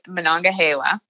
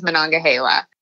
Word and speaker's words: Monongahela: I pronounce it Monongahela, but my mom, I asked her the other day, and she says Monongahela.